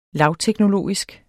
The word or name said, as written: lavteknologisk